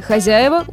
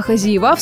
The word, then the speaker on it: хозяева: В слове «хозяева» ударение всегда падает на «я», а «хозяева», в свою очередь, обыкновенное просторечие, совершенно недопустимое в литературной речи, да и в речи вообще.